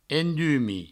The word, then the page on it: endumis: endumi